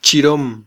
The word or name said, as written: chirom